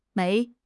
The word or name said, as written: 枚